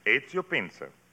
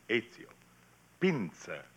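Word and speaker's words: Ezio Pinza: That is right. Ezio Pinzer. – How's that again? – Ezio. E-Z-I-O. Ezio. Pinzer. P as in Peter, I-N-Z-A.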